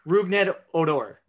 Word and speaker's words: Rougned Odor: And the reason I brought this up is recently the Yankees traded for, and I'm going to butcher his name, Roned Odor. – Rougned Odor.